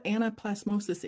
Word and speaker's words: anaplasmosis: Well, can you tell us what anaplasmosis is? And I'm not even sure I'm saying that correctly. – Yeah, you got it.